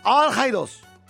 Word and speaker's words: Al-Haidos: Es Hassan Khalid Al-Haidos. Así es la pronunciación.